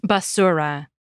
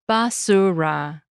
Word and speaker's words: basura: In contrast, the Spanish word for garbage is basura. We pronounce each vowel sound in that word clearly, basura. None of them are swallowed.